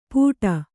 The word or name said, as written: ಪುಟ